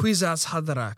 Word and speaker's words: Kwisatz Haderach: He's also the Kwisatz, how do you say it? The Kwisatz Haderach. – Thank you.